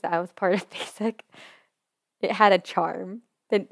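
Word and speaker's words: BASYK: That's honestly, another story, because, I mean, okay, saying that you're a part of a group called Basic, but it's not spelled like how basic the word is spelled, it's awful.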